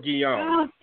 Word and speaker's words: Guillaume: Am I saying it correctly? – Shoot, I knew how to say it until you said something. Robert Guillaume. – Oh, Robert Guillaume. – Thank you. Thank you. I knew I was wrong. Completely wrong. But anyway,